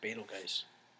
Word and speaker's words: Betelgeuse: It's Betelgeuse.